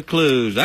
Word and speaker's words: kluge: Busted out a kluge. – I got you a kluge. – Fixes glitch. – Kluge. Kluge. I don't know why it's pronounced that way, but it is.